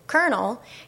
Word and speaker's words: colonel: So an irregular word like colonel, which you or I would be able to pronounce because we've memorized that that particular pattern of letters is pronounced as colonel,